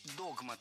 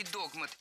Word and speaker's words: догмат: Хотя раньше следовало говорить догмат. Но филологи пересмотрели ударение в этом слове и приняли двойную норму. Поэтому ошибиться в этом слове невозможно. И догмат, и догмат находятся в пределах литературной нормы.